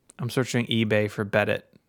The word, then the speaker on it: Bedit: It's spelled Bedit like Reddit with a B, which is fun.